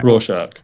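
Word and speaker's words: Rorschach: Raw shark.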